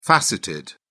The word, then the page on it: faceted: British English